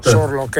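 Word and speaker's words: Sørloth: Sorloth, ecco, grazie per la pronuncia, cioè sono dei giganti, dei Marcantoni, dei granatieri.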